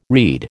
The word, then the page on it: read: American English